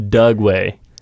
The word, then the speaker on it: Dugway: It's called the Dugway. – Dugway. Yeah, you started with a D.